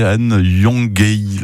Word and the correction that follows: Yongoyeli: Bah non, là c'est pas terrible. – Yongoyeli. – Yongoyeli. Oui.